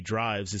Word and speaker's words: Driivz: Drivz, spelled D-R-I-I-V-Z, develops smart, or maybe Drivz is how they pronounce it, develops smart EV charging and energy management software.